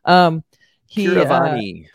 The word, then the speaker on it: Keeravani: Keeravani. – M.M. Keeravani.